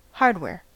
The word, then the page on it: hardware: American English